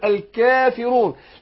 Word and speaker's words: الكافرون: فأرقق الكاف أقول الكاء، والألف تبع للكاف، أرققه تبع للكاف أقول الكافرون، لأن أنا لو فخمت الكاف هتبقى قاف وهفخم تبع لها الإيه؟ الألف.